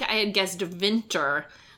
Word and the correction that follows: vintner: which is that's me mispronouncing the word vintner, which is an old-timey word for a wine merchant.